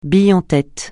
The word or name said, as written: bille en tête